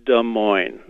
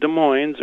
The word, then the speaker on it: Des Moines: And Des Moines, it's Des Moines. Not Des Moines or Des Moines.